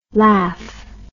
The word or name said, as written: laff